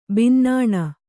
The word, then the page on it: ಬಿನ್ನಾಣ: binnāṇa